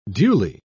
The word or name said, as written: duly